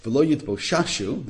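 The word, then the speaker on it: V'lo Yidboshashu (ולא יתבוששו): So, V'lo Yidboshishu, how you want me to pronounce that? V'lo Yidboshashu, there you go.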